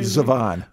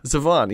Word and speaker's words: Zevon: How do you say Zevon? – Zevon.